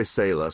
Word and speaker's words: Sala: There's a couple of different ways to say it. We say Sala, so it means to pause and reflect.